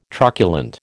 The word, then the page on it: truculent: American English